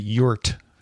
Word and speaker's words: EURT: I do want to pronounce it yurt, the E-U-R-T.